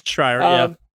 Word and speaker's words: Schreier: And, it looks like, Jason, was it Schreier? I think so. Did I butcher his name? I'm sorry. – It's Schreier, Yeah.